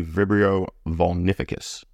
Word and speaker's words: Vibrio vulnificus: And type three is a gram-negative marine organism, most frequently Vibrio vulnificus.